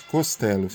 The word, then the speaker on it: Costello: Abbott Castelo, Jackson 5, aliás, Abbott Costello, falei errado o nome.